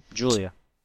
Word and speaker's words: Giulia: G-I-U-L-I-A? – Julia. – Oh. – That's like the Italian way of spelling it. – You would know. – You get G-I together, you get J.